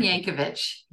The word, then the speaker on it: Yankovich: Karen Yankovich, yeah. – Not Yankovic, like Weird Al.